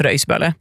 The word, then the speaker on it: Rågetsböle: På Åland har vi också, vet du, vi har Bölsvik som uttalas Börsvik och Rågetsböle som uttalas Röjsböle.